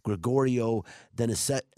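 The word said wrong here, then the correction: Grigori Denisenko: Grigory Denisenko.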